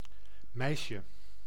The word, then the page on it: meisje: /ˈmɛi̯.ʃə/; /ˈmɛɪ̯.ʃə/